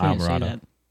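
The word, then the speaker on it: Islamorada: I forgot to say, Grandma said instrumental Isla Morada. – Oh, no, that's Isle Morada. Isla Morada. – Nah, but it's pronounced Isle Morada.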